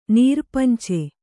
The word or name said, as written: ನೀರ್ಪಂಚೆ